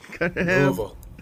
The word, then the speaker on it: Louisville: Or did I say that right? Is it Louisville? – Louisville. – Okay, Louisville.